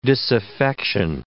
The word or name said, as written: disaffection